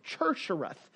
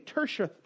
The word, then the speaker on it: Tirshatha: Nehemiah in the Bible is also given the title of Tershareth. Tershareth. And you spell that, if you're looking for spelling, is T-I-R-S-H-A-T-H-A. Once again, T-I-R-S-H-A-T-H-A. Tershareth.